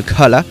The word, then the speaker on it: Colour: J'insiste sur la prononciation à l'anglaise de Colour, car le gratteux Vernon Redd est natif de Londres.